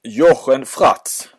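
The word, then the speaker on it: Jochen Fraatz: Och jag läser Jochen Fratz. Är det rätt uttalat? – Ja, det är rätt uttalat.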